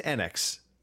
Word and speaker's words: Enix: Apparently it is Enix. I have heard now, supposedly that's the way it's called.